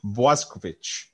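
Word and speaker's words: Bošković: Boskovich. How's that sound? – That's perfect.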